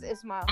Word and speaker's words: Ismail: Well, everybody keeps calling me I Smile, right? Because it's spelled like that. It's Ismail.